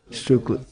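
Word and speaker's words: štrukli: Stukla? And stukli? Stukli. Did I say it right? And then there's in Slovenia, it's something similar, right? It has a different name. Same thing? It's the same thing. It's the same. Strukli.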